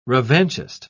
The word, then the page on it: revanchist: British English